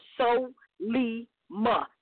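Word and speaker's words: Solima: And it wasn't bad, but her name is already written in syllables. It's three syllables. So-li-ma. It's spelled exactly the way it sounds.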